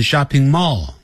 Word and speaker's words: shopping mall: Very 就是非常 big，不要念成 big， big it 短元音 shopping mall，不要念成 shopping mall， shopping mall。这 shopping mall 这声音呢在 shopping 比较高， mall 那就比较低，它是有个依据的。